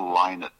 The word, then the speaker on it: Lynott: It's Phil Lynott, not Lynott. Okay. I know it's like Bowie and Bowie. It's always, there always seems to be some discrepancy about how it's pronounced, but I'm assured that it is Philip Lynott.